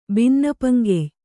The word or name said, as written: ಬಿನ್ನಪಂಗೆಯ್